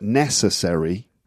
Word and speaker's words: necessary: So when you get a word like necessary, mm-hmm. – the stress is on the first syllable. – And necessary. – Not necessary. – Which is like accessory. – Sounds like accessory. – Yeah. – But necessary, right? The point here is that you've got necessary, Now, those unstressed syllables, mm-hmm. the vowel sounds become very weak.